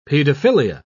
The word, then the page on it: paedophilia: British English